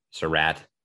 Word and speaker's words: Surratt: And then Josh Surratt, who is Surratt. Thank you. Pronunciation guide has come out, folks.